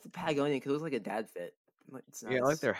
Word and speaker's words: Patagonia: Like Patagonia, I think that's how you say it. – Yeah, Patagonia.